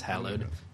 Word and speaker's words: Hallowed: Number five, Hallowed Dreams, or Hallowed Dreams, but I think it's pronounced Hallowed. H-A-L-L-O-W-E-D.